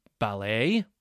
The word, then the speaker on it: ballet: Okay, so what are some other common words with a silent T? How about ballet? B-A-L-L-E-T. Hustle? H-U-S-T-L-E. And its friend, bustle? B-U-S-T-L-E.